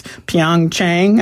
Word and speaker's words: Pyeongchang: I looked on the website, and I looked up the place in Korea, and I'm going to mispronounce it, but it's Pyeongchang, I think.